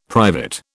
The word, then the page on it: private: British English